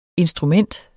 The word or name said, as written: instrument